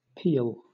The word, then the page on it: peal: British English